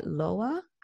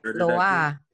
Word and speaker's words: Loa: So in Vodou, however, possession by Loa, I believe is how it's pronounced, L-O-A. – Loa.